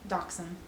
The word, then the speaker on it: dachshund: So how do you pronounce dash hound? – Dachshund.